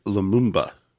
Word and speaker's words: Lumumba: Her duties included handing out flyers and bartending under her boss, Patrick Lumumba.